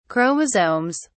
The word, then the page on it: chromosomes: British English